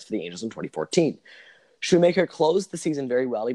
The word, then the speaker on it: Shoemaker: He's one of the few that have their name spelled Shoemaker and pronounce it Shoemaker. A lot of people are Schumacher, all sorts of garbage.